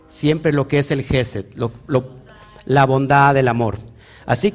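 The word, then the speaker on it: Jésed: Bueno, lo que miras ahí, que es la esfera de GESET, lo pongo aquí para los que todavía no no saben cómo se pronuncia se llama Geset.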